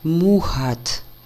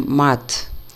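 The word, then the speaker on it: Маат: В любом случае, звук на самом деле больше как мухат, чем мат.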